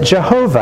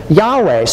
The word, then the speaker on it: Yahweh: As older generations pronounced it, Jehovah, as modern generations tend to pronounce it, Yahweh.